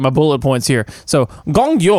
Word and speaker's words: Gong Yoo: Gong Yoo.